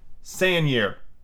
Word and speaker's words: Seigneur: Seigneur. That's how you pronounce that because it's French.